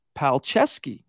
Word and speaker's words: Palczewski: You would never know it looking at the way his name is spelled, P-A-L-C-Z-E-W-S-K-I, but it is in fact pronounced Palcheski.